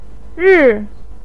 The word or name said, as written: ri4